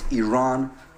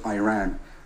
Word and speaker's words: Iran: First of all, you support the Iranian people, but you're pronouncing our country's name wrong. Our country is named Iran, not Iran.